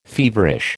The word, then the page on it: feverish: American English